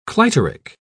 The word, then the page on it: clitoric: British English